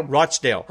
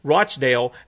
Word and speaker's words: Rochdale: So if you are there in, how do you pronounce that, Rochdale? Is that correct? – Rochdale. – Okay, Rochdale. Then go and support those guys there.